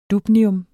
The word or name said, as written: dubnium